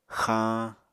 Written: xañ

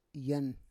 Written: yan

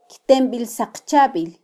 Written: kytembilsaqchabil

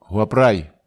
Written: huaprai

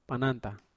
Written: pananta

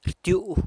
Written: rtyu'uh

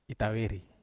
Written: taveri

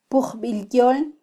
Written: pujbilyol

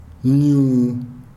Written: nuun

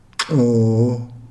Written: xoo

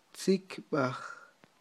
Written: tzikyb'aj

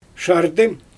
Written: ʃardɯ

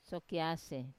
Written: sokiase

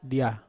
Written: dia

